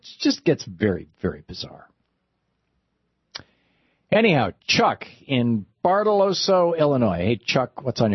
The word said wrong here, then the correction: Bartelso: Hey, Tom, you always meant to pronounce that. It's Bartelso. – Bartelso, thank you.